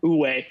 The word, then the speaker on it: Wu Wei: Did I pronounce that right? – Wu Wei. Yeah.